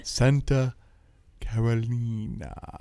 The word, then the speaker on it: Santa Carolina: You didn't say it right. Say it like the CD that accompanied the wine tasting back in the day. – I don't know if I have the energy. Santa Carolina. – Very good.